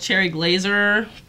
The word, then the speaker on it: Cherry Glazer: The next band is called Cherry Glazer. Two R's at the end, so you gotta say it like right girl. You gotta say Cherry Glazer. Yeah, right girl, Glazer.